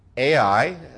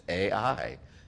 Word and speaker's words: Ai: Joshua sent men from Jericho to Ai, Elder Mike. That actually was the correct pronunciation. You know, I've studied this. Some say I, okay? But actually, I think the pronunciation is Ai.